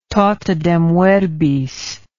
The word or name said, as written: totidem verbis